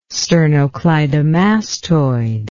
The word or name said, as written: sternocleidomastoid